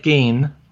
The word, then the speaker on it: Gein: In the 1960s, there was a guy in America called Ed Gein, or Ed Gein, depending on how you want to pronounce it,